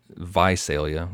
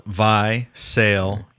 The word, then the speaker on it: Visalia: I mean, you tack in the Visalia. We said it wrong. Isn't that the way to say it? – Yeah, we got corrected. Visalia.